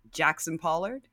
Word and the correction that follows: Jackson Pollock: Yeah, Jackson Pollock, sure.